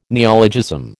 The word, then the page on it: neologism: American English